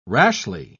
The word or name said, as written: rashly